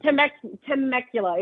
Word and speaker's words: Temecula: How do you say that? Temecula? I can't say that. – Temecula. It's Southern California.